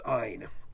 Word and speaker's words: Ayn: I loved the mnemonic that someone had written that said, in keeping with their philosophy of selfishness, Ayn rhymes with mine, which I thought was pretty clever.